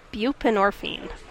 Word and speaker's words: buprenorphine: And one of them is a tax credit for providers of, how do you pronounce that? – Buprenorphine.